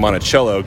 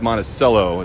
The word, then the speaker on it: Monticello: I've been saying Monticello because in Virginia, you know, with yes. Charlottesville, yep. Jefferson's home there, we say Monticello. So I'll make that note. It's Monticello is how they pronounce it up in Minnesota.